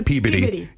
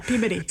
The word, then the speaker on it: Peabody: Or Peabody. It's Peabody. – Peabody. – It's just the local dialect.